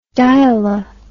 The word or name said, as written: diala